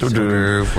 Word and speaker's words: hors d'oeuvre: That's when I took the card from him and I said, this is pronounced hors d'oeuvre. – We're not going to hold this against you. – It's a French thing.